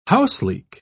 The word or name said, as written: houseleek